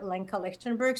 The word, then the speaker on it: Lenka Lichtenberg: And I also can be found on a YouTube channel under Lenka Lichtenberg.